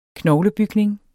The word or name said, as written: knoglebygning